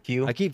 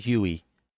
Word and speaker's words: Hue: I think it's funny is that how do you pronounce that? What? Hue, the light Hue? System. – Hue. – They call it Huey over in EU.